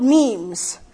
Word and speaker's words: meme: They're called memes. They're not memes. It's spelled M-E-M-E. They're pronounced memes.